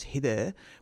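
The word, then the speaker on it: hidder: But in Danish, you can say, you can use a verb which is hider, which is actually spelled with a double D. So H-I-double-D-E-R from memory. So the double D sound gets pronounced more, like, almost like a T-H. It's a kind of a F sound. And it just sort of sounds very slurry.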